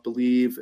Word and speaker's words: Bleav: The Believe in Chiefs podcast, if you just, you know, it's a kind of an odd spelling, B-L-E-A-V, but it is pronounced Believe.